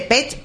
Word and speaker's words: Pet: ¿Pet o pit? – Pet. – O sea, esto sí se lee como se escribe.